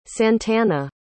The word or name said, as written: santana